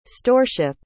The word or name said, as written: storeship